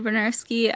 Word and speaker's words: Bryniarski: I'm Anna Bryniarski. I write reviews for Game Cola. – I think you mispronounced your last name. There's an extra I in there somewhere.